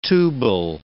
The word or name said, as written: tubal